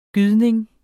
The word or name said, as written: gydning